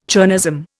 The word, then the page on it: chernozem: Indian